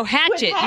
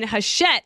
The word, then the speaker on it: Hachette: No, Hachette, you said, with Hachette. And it is spelled like that, but it's like French. And I said, oh, you mean Hachette.